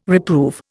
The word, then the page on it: reprove: Indian